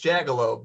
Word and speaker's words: Jagiełło: It's Jagiełło, but that's really close to the Polish pronunciation. That's more correct.